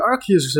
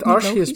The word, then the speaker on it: Arceus: ik vind Arceus beter klinken, of uh, Arceus. – Ja, volgens mij is dat ook hoe je dat fonetisch normaal gesproken zou uitspreken. Als er een E naast de C staat.